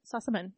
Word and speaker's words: Sossamon: Sossamon.